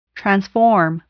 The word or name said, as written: transform